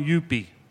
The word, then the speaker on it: Youppi: We're going Yuppie. – Yuppie? – Yep. – Okay. How do you spell that? – Y-O-U-P-P-I.